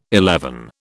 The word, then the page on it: eleven: British English